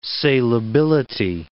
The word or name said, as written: salability